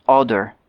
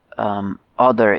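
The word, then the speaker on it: Óðr: So Audr is O-D-R. We spell it O-D-U-R generally in English so that we know how to pronounce it.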